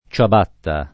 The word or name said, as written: ciabatta